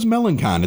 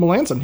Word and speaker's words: Melancon: Is that how you pronounce his name? I always thought it was Melancon. It's Melancon? – Melancon, yeah.